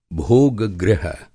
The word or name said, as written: भेद